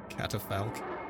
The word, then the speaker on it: catafalque: Before me was a catafalque. Is that the right way to pronounce that? Catafalque? Hello? – Catafalque. – Catafalque. All right.